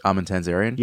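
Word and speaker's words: Armin Tamzarian: Armin Tanzarian? – Yes.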